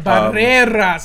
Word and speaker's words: Barrera: Barrera, say it with Barrera. Me. Yeah, Ere con ere cigarro. That's how I learned this in Spanish. They're like, this is how you roll your R's.